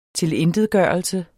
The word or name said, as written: tilintetgørelse